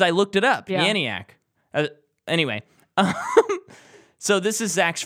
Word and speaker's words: Janiak: And, oh, Janiak, that's how you say her name, I think.